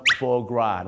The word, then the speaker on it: foie gras: foie gras